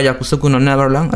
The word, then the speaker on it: Yakusoku no Neverland: Volviendo a Yakusoku no Neverland, ¿así la pronuncié bien? – Sí, está bien.